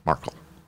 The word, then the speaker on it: Markle: Is that how you say it? Markle? Yeah. – Markle. – Markle. So you emphasize A? – Markle.